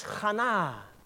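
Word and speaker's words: Ghana (חנה): Spreek je die naam uit als Ghana. Ghana. Nou, dat klinkt een beetje op Ghana.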